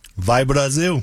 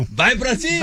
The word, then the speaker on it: Vai Brasil: Vai Brasil. – Vai Brasil. – Vai Brasil.